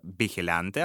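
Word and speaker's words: vigilante: Есть в испанском языке такое слово «бихелянте», оно произносится, пишется как «вигиланте».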